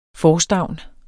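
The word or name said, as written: FORSTÆVNENES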